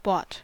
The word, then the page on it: bought: American English